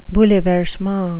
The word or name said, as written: bouleversement